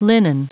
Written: linen

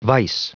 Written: vise

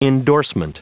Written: endorsement

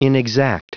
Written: inexact

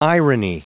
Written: irony

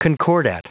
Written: concordat

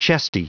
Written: chesty

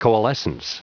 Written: coalescence

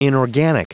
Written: inorganic

